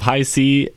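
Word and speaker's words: Hi-C: I love skateboarding, I love high C, and I love my Dynaglide. Those are my 3 favorite things. – I always pronounce that hick. I didn't until somebody actually said it in front of me.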